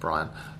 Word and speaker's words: Brahian: His first name is spelled B-R-A-H-I-A-N. But it's pronounced Brian.